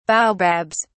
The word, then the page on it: baobabs: British English